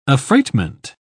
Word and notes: affreightment: English; British English

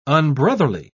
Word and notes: unbrotherly: British English

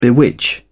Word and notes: bewitch: British English

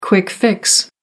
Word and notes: quick fix: American English

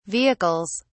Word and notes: vehicles: British English

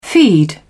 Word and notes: feed: British English

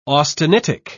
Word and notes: austenitic: British English

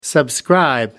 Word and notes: subscribe: American English

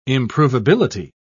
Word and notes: improvability: British English

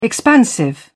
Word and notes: expansive: British English

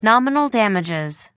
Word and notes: nominal damages: American English

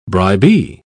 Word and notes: bribee: British English

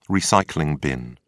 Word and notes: recycling bin: British English

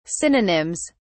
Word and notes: synonyms: British English

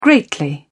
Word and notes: greatly: British English

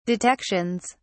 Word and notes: detections: British English

